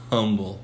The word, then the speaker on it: Humble: Um, grew up in North Houston in the town of Humble, spelled like humble, but it's humble.